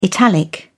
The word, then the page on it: italic: British English